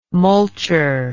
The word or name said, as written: mulcher